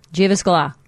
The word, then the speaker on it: Jyväskylä: Jyväskylä. – Jyväskylä. Why do you pronounce it like Greek? – Because that's my go-to language.